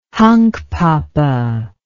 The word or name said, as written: hunkpapa